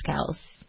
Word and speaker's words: Kelce: That's how everyone pronounces his name. It's Kelce. – It's Kelce. – It's one of those things where his name is actually pronounced one way, Kelce. But he rolls with the punches of people mispronouncing it and goes with it.